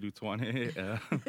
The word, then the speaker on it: Utuone: I am David Utuone, 31, gay male.